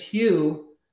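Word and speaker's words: Hu: His name is Hugh, but it's spelled H-U, which is Sanskrit for white light or God.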